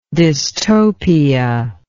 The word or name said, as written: dystopianism